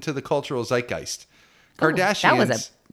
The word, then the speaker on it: zeitgeist: Cultural zeitgeist.